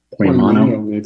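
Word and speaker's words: Poimano (ποιμαίνω): Is that how you pronounce it, everyone? Do I have that right? Or any other pronunciations? Poimano? Poimano, yeah.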